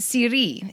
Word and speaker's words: Siri: Siri, it's the accent on the second one.